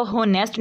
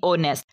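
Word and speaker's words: honest: इसी तरह से साइलेंट लेटर एच है, इसको होनेस्ट नहीं पढ़ना, एच जो है वो साइलेंट कर देना, ये पढ़ेंगे ओ नेस्ट, ओ वाली आवाज़ आएगी ओ नेस्ट।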